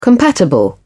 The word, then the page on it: compatible: British English